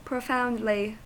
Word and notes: profoundly: American English

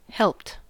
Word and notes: helped: American English

